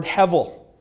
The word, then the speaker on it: hevel: And that Hebrew word for vanity is the word hevel, it rhymes with level.